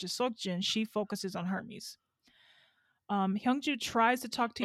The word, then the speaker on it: Hermes: Hermes? – Hermes is the Greek. Hermes is the brand.